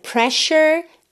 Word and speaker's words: pressure: All that pressure got you down. Então olha essa palavra, pressure, se escreve P-R-E, S-S-U-R-E. Talvez você fosse pensar em algo como pressure, né? Pressure, pressure, ok?